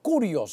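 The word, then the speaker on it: curios (κύριος): Es una palabra que se dice curios, se escribe curios, y es supremo en autoridad, es controlador, es señor, como un título de respeto, es soberano, ungido, el amo, es el dueño.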